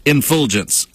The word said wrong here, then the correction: effulgence: I'm sorry. Effulgence.